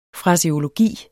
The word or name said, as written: FRASEOLOGIEN